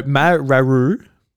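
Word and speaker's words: Mawaru: So the first one is Marawa Penguin Drum. – Is what? – What? – The last syllable I half laughed, and it came as Marawa. No, Mararu Penguin Drum.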